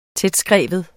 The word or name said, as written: tætskreven